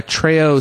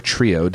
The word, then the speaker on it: Treo: I'm using I have a Treo 750W, or Trio, depending on how you want to pronounce it, which is a new it's the GSM Trio running Windows Mobile.